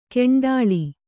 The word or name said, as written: ಕಂಡಾಳು